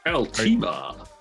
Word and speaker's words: Altima: Okay, do we call it Altima or Altima? – Altima. I'm renting an Altima from Los Angeles International Airport. – That's the correct pronunciation.